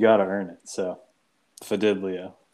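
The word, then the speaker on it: Fidelio: It's spelled like it sounds. – Yeah. – Like it sounds. – Fidilio. – Fidilio.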